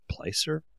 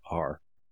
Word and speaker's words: Plaisir: is it Placer or Placier? Now you pronounce it Plaisir.